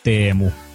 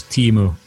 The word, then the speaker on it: Teemu: The way how you pronounce it in correct Finnish is Teemu. – I'm not going to be able to recreate that. – Yeah, but you can do the English version, which is Timu.